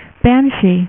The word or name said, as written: banshee